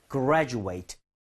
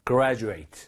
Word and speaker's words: graduate: 졸업하다라고 쓸 때는 age이기 때문에 graduate, graduate. – Graduate. – 아하. – Graduate. – 하지만 이 똑같은 스펠링이 명사가 될 때가 있어요. 이럴 때는 졸업생이라는 뜻인데 그때는 ate를 age이 아니라 ut처럼 발음을 하셔야 됩니다. 제가 발음 들려드리도록 할게요. ut 활용해서 graduate, graduate.